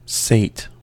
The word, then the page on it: sate: American English